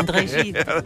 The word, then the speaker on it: Gide: Lei scriverà solo questo libro, è il 1949, lo porterà 15 anni dopo a André Gide. L'ho pronunciato bene, è scritto Gide? – Sì, sì, sì, è scritto benissimo, bene. – André Gide.